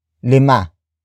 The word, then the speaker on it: lima: The next word is lima. Lima. Lima. Lima is spelled as L-I-M-A.